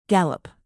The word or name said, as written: gallop